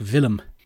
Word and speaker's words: Willem: Looks like the English had gone off the name, but the Dutch were very fond of it. Although I'm pretty sure they pronounced it more like Willem.